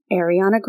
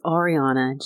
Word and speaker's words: Ariana: Some of his favorite artists were Beyonce, Rihanna, and Ariana Grande. – It's Ariana. Just ask our friend Zach. – Did I say Ariana? – You did. – Ariana.